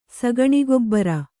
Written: ಸಗಣಿಗೊಬ್ಬರ

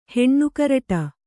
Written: ಹೆಣ್ಣುಕರಟ

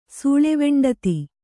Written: ಸೂಳೆವೆಂಡತಿ

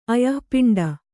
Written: ಅಯಃಪಿಂಡ

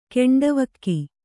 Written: ಗಂಡುವಿಕ್ಕೆ

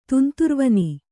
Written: ತುಂತುರ್ವನಿ

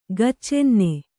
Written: ಗಚ್ಚೆನ್ನೆ

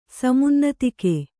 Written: ಸಮುನ್ನತಿಕೆ

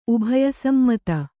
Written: ಉಭಯಸಮ್ಮತ

